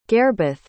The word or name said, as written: gairbith